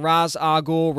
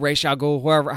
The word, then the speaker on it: Ra's al Ghul: who's playing Ra's al Ghul, Ra's al Ghul, however you want to pronounce it, although that is explained during this at some point.